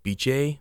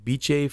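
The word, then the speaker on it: Bitche: And this took place in, and I'm not shitting you, it's probably pronounced Biche, but it's spelt bitch with an E at the end of it. Biche, France.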